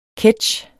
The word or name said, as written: ketch